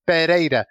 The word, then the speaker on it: Pereira: If there's just one R, it's Pereira, like you said.